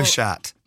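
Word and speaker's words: chaat: And then, am I right in thinking it's pronounced shat? Shat. – Aloe shat.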